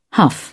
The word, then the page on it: huff: British English